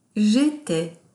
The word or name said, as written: jetais